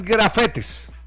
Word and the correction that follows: gafete: Ah, ok. – Es gafete.